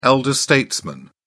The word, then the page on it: elder statesman: British English